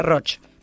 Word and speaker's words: Roig: Se escribe Roig y se pronuncia Roch. Porque es catalán.